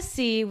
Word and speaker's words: Seay: So Martez, his last name, it's pronounced Seay, but it's spelled S-E-A-Y, just so you guys have a visual for that.